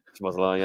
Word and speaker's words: Szoboszlai: Obviously, they've signed really well with Gravan Burke and Szoboszlai. Oh, how I pronounce his name. I think that's how you say it. – Szoboszlai, yeah.